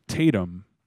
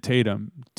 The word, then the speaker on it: Tatum: My name is Slate Tatum. – How do you spell that? – It's S-L-A-T-E, and then... – Oh, okay. – And then Tatum, T-A-T-U-M.